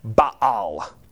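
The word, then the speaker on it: Ba'al: Actually, you'd pronounce that Baal. There's an apostrophe there.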